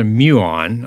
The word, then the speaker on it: muon: So it's called a muon um, after the Greek letter mu. Uh, hardly anybody knows what a muon is except for a few physicists.